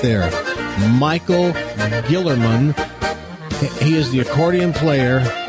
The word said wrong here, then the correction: Killerman: It's not? Killerman. I'm sorry, Killerman. I mispronounced his name.